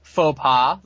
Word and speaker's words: faux pas: It looks like Sexy Star wasn't the only person to commit a huge faux pas. – They learned how to spell that. Did you know there's an X in there? You don't even hear it.